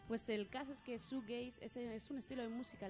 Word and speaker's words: shoegazing: que esta vez vamos a hablaros del shoegaze. No sé si lo he dicho bien, Brian, ¿lo he dicho bien? Shoegazing, está bien dicho, vale.